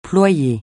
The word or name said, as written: ployer